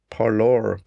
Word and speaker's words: Parler: So I will also talk about a service called Parlor, probably a pronunciation, the French one, but it's spelled P-A-R-L-E-R.